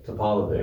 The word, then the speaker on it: Topalovic: It's Topalovic.